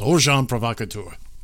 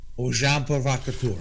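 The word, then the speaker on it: agent provocateur: Think of agent. It's spelled agent, but you pronounce it with a very fancy French kind of word. Is Ojan provocateur. – Ojan provocateur.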